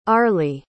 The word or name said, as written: arley